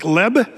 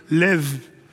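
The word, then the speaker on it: lev (לב): The Hebrew word, it looks like leb, but when you see a B in the Hebrew, it's actually more of a V sound. So when you say heart in Hebrew, it's lev. Lev is how you say that.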